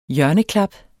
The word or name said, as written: hjørneklap